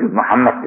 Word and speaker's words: Muhammed: Ahmet'i söyleriz de Mehmet'i söylerken Mehmet deriz. Halbuki Muhammed deriz. Muhammed yazı da aynandır. Fakat telaffuzu hatalı konuşuruz.